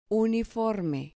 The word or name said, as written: uniforme